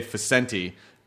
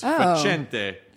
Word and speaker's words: Facente: But my family did the American pronunciation because their last name they say Facenti. But it's Facente. – Facente. – It should be Facente.